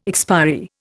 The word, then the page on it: expiry: Indian